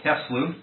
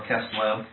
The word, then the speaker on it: Kislev: And so this was actually on the fourth day of this lunar month, which is called Kislev, or Kislev, depending on how you want to pronounce the vav in that Hebrew word.